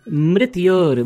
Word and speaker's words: mrityor: Mitaror mushki. – Mitaror mushki. No, it is mrityor mukshya. Mrityor. Yes. Mrityor.